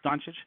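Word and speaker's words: Dončić: As a side note, I think it's pronounced Doncic. I've been very confident to my girlfriend about that it's Doncic, and she just gave up and said, "Oh, it's Lukey D."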